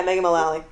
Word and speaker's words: Mullally: Yeah, Megan Mullally.